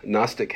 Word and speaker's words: Gnostic: In the second century, we call them Gnostic, the G is silent, Gnostic heretics, claiming apostolic authority, wrote their own books and disseminated them widely.